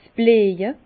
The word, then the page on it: 's Blieje: Bas Rhin; Herrlisheim